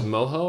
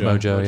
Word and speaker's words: Mojo: I think it was Moho or something. – Mojo, yeah. Okay. – I was pronouncing like Spanish.